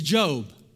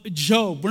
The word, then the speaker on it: Job: It's not Job, it's Job. All right, so in the book, in the Old Testament, you find it right before the Psalms. It's the book of Job.